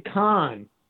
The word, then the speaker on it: Kahan: Now, how do you usually pronounce your name, Cahan or Cahan? – I say Cahan. But if you say that to a computer, it'll spell it wrong. – Right. Yeah. – If you say Kahan, it'll spell it right.